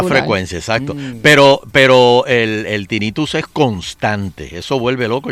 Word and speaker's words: tinnitus: Me dice aquí tinnitus, se dice así.